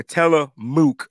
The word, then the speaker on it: Tillamook: Tillamook. Look, she's telling me how to pronounce it. No doubt. M-O-O-K. I got you, Mook.